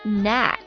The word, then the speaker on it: knack: Knack, K 不发音 ,K-N-A-C-K, knack, 名词诀窍、本领、熟练的技术。